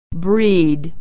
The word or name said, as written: breed